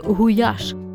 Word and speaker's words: гуляш: Самі угорці вимовляють цю назву як гуляш – від слова гуляс – пастух великої рогатої худоби.